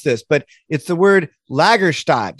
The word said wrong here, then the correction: Lagerstätte: Did I did I say that right? – Yeah, the German word would be Lagerstätten or Lagerstätte in singular, which is a, a fossil locality with special preservational circumstances yeah, those are supposed to be huge localities of fossils.